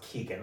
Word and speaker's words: Keoghan: Barry, I don't need a mansplain to you, but I'm pretty sure your last name is just pronounced Keegan.